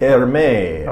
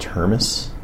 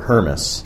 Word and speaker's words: Hermes: Actually, well, the true story is that my last name is pronounced Hermes. It's German. And so my great-grandparents immigrated to the U.S. They settled in North Dakota, and they were Hermes.